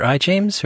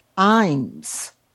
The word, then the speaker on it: Ijames: James or I. James? Or do we know how to pronounce it? – I saw something that said it's pronounced Imes. – Imes, but, okay. – But I have no idea. – It's spelled capital I-J-A-M-E-S.